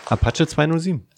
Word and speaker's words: Apache 207: Apache 207.